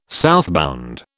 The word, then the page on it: southbound: British English